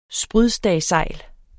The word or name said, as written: sprydstagsejl